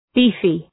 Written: beefy

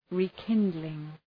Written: rekindling

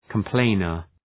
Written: complainer

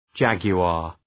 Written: jaguar

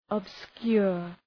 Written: obscure

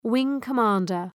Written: wing-commander

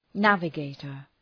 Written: navigator

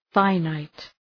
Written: finite

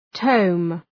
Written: tome

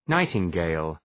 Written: nightingale

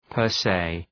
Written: per se

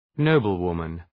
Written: noblewoman

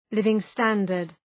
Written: living standard

